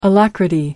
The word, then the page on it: alacrity: American English